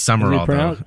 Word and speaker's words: Sumrall: He became part of S-U-M-R-A-L-L. – I know, it's weird. It is Summerall. – Is it pronounced Summerall? – Yeah. – I feel like there's a letter missing. – It is. It's not spelled right, but that's how it's pronounced.